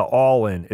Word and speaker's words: Ollin: and then shortly after got the All-In. It's spelled O-L-L-I-N, but they pronounce it All-In.